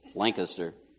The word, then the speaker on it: Lancaster: If you go a little north of Lancaster, that's the right way to say it, Lancaster, there's a town called Lititz.